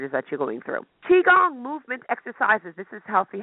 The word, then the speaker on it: Qigong: So Qigong, it's pronounced Qigong, but it's spelled Qigong. Qigong.